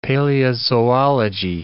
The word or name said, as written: paleozoology